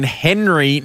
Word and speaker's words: Hnry: Join Henry now. It's spelled HNRY.